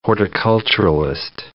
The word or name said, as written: horticulturalist